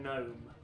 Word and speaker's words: gnome: Pneumatic, knowledge, and gnome. Oh, I think that was just the Big Nerd alert. – Silent letters. – They have silent letters at the beginning, and that, I'm afraid, is the end of round one with a triumphant 3-0 score to Big Nerd.